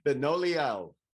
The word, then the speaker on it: Benoliel: And Shelly, your name is pronounced Benoit? – Benoliel. – Benoliel. – Correct.